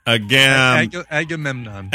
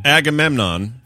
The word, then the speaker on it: Agamemnon: It's not a Jamaican gay man. – All those extra syllables. Use them. Agamemnon. – Agamemnon.